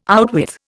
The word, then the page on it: outwith: Indian